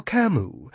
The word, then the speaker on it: Camus: You're Camus. It rhymes, but has a silent S.